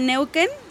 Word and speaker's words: Neuquén: Reside en la ciudad de Neuquén, ¿está bien pronunciado? – Sí.